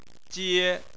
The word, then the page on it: jie: 1